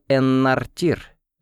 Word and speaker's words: Эннартир: Мы-то называли монарха Арканора так, но на самом деле он Эннартир, с ударением на последнем слоге.